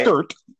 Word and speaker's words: skrrt: It they spell it S-K-R-R-T, right. – Skirt.